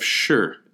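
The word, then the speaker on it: Shi (士): S H I is how you write this down in modern day romanization, and it's pronounced like the English word sure. You know, like, hey, yeah, sure, I'll go. Um, the sure.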